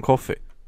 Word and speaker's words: Coffey: John Coffey. Like a drink but not spelled the same.